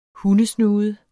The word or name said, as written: hundesnude